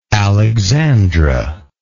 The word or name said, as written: Alexandra